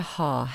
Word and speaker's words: Har: So for example, in Kundalini yoga, we do a lot by rolling the R. So for the mantra HAR, H-A-R. If you can't roll your R's, which I can't, you use your tongue to create more of a D sound. So you flick the tongue on the roof of the mouth. So hud, hud, hud, hud, instead of har, har, har.